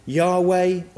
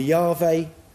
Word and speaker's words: Yahweh: In Hebrew, the name is essentially Y-H-V-H. And they're pronounced, those letters are pronounced Yod, He, Vah, Hey. Some pronounce the name Yahweh or Yahweh, but many people consider the name too sacred and holy to be spoken.